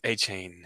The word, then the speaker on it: Achane: You're looking at Achane.